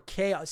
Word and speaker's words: Chao: The only saving grace in this game is that the music is baller, there's going to be controversy about this, whether it's Chao or Chaos. See, to me, it looks like it's pronounced K-O or K-A because Chaos Emeralds, and it's C-H-A-O. But we, I think, decided during the podcast that it was Chao, so that's what we're calling it.